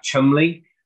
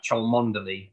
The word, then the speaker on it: Cholmondeley: The main policeman, he's called Jack Chumley, but it looks like it should be pronounced Jack Cholmondeley, but it's just one of those weird, it's just one of those weird British things.